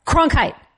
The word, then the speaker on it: Cronkite: Walter Cronkite. Cronkite. – She said it right.